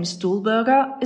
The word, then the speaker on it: Stuhlberger: My last name Stuhlberger is actually a combination of the German words for chair and mountain. If you have trouble pronouncing it correctly, just think of the chair's little brother, the stool, and then put Berger at the end.